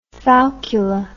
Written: falcula

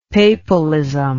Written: papalism

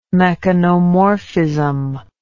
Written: mechanomorphism

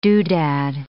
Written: doodad

